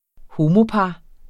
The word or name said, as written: homopar